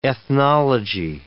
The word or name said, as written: ethnology